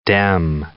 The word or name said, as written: Dem